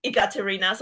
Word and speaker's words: Ekaterina: So my full name, my legal name is Ekaterina. So it's spelled with E, Ekaterina. So a lot of people make fun of me. Saying, oh, is it like e-commerce? Is it like e-business? Ekaterina.